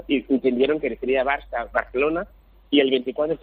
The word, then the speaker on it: Barça: El único que sería de España era primero Spain en inglés y segundo Barca. – Barça, ¿no? Barça, claro, Barca. – Claro, Barça, Barca, pero claro, como que en inglés la C catalana no existe, y si pronunciamos Barca, ¿no?